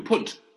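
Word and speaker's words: Pünd: did I pronounce Punt correctly? – You do, Punt. Atticus Punt. Punt is German for pound.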